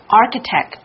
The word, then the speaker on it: architect: So Frank Gehry is the most praised architect of the 20th century.